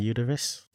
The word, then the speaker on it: Eurydice: Orpheus and Eurydice. – Yes. Am I saying that Eurydice, right? Eurydice.